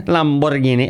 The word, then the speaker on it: Lamborghini: Lamborghini. Alan's listening, so I said it right.